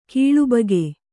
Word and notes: ಕೂಳಬಾಕ: Kannada

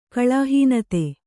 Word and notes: ಕಳಾಹೀನತೆ: Kannada

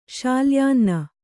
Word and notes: ಶಾಲ್ಯಾನ್ನ: śalyānna

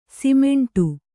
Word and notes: ಸಿಮೆಂಟು: simeṇṭu; Kannada

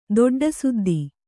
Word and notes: ದೊಡ್ಡಸುದ್ದಿ: Kannada

